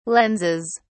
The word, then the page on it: lenses: British English